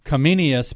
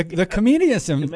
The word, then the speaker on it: Comenius: And I'm going to say Comenius, but I know I probably mispronounced that. – You know, that was perfect.